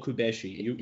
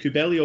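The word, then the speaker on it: Cubelli: Many times before, but is it Kubeli or Kubeshi? – Well, it depends on who's pronouncing. Kubeli is fine. Yeah. – All right. – Kubeli, no problem. – Okay. – It's a Buenos Aires thing.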